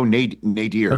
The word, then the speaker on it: nadir: I go nadir. – According to Oxford Dictionary, both pronunciations are correct.